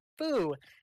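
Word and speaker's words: fu (風): And the kanji, um, this kanji means wind, meaning wind, in, in Japanese is used on the cover, which can be pronounced as fu in Japanese.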